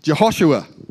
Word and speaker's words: Jehoshua: And so, actually I can pronounce it, I've got it written down. That was clever of me. Jehoshua, which means Jehovah is salvation.